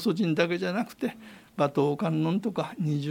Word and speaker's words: 馬頭: 馬の頭と書いて馬頭と読むんですけれども、